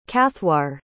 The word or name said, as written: cathaoir